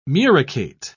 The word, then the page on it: muricate: British English